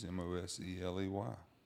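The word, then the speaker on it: Moseley: My name's Will Mosley. You can find me at Will Mosley Music. Mosley spelled with two E's, Mosley.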